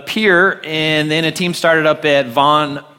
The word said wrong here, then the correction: Varn: Park, if I can say that correctly. What's that? Varn. There you go.